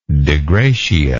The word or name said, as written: de gratia